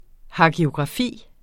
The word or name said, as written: hagiografi